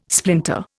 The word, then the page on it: splinter: Indian